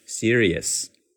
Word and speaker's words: serious: 所以这个单词呢，在生活中就被老外最终读成这个版本，读成了 serious, serious。你可以把它拆分为 s e e c，然后是 r i re，最后再来一个 yes, y e s，所以是 serious, serious。